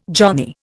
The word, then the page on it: johnny: Indian